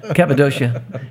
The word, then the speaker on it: Cappadocia: Cappadocia.